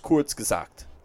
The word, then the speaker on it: Kurzgesagt: It's Kurzgesagt.